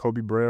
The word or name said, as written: Koby Brea